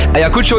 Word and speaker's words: Ayacucho: Xenia, als je naar Ice Adventure gaat om allemaal avontuurlijke dingen te shoppen, dan kan je kopen bij hun exclusieve huismerk Ayacucho. Ayacucho. Ja, zo moet ik het uitspreken. Ayacucho is vernoemd naar een stad en regio in een land in Zuid-Amerika.